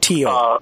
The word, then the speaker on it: Thiel: And, you know, Peter Thiel, for example, an early investor in Facebook. – I believe you pronounce that Thiel, even though it's spelled T-H. – T-H-I-E-L, Peter mm-hmm.